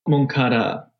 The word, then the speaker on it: Moncada: Moncada. – Moncada. – Yeah. Pablo and Moncada, I feel like it rhymes, so it happens pretty often, yeah.